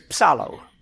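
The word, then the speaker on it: psalo (ψάλλω): And the word that is translated as sing in the New Testament is psalo, P-S-A-L-O. L-L-O, long O.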